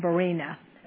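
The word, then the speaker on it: Araunah: So later David moves it to a threshing floor of Arena. That's pronounced right.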